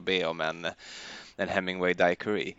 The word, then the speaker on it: Daiquiri: Apropå det, jag har förresten hört att det inte ska uttalas daiquiri utan att eh, det ska typ uttalas daiquiri. Det har jag också hört, men jag tycker det är svårt.